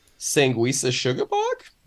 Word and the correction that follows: Sanguisugabogg: Nope. Sure. it's Sanguisugabogg. Close.